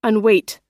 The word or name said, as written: unweight